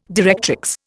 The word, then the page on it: directrix: Indian